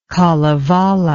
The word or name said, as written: kalevala